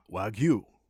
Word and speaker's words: Wagyu: Wagyu.